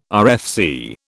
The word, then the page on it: RFC: British English